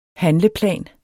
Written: handleplan

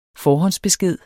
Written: besked

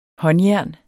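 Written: håndjern

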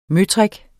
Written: møtrik

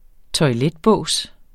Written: toiletbås

